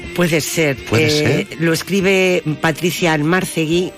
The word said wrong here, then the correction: Almarcegui: o Almarcegui, perdón, que no lleva tilde.